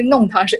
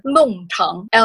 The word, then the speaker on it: 弄堂: 所以我们说的是，这个是弄堂，是 N-O-N-G, 第四声。结果正确的发音是弄堂 ,L-O-N-G, 第四声。 – 上海屋上海话就是好像是弄堂，我们说怀旧上海的这种弄堂还有这些老房子都是必须去的。